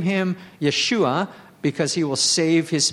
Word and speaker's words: Yeshua: Okay, Yeshua in Hebrew is, there's a silent letter on the end of it.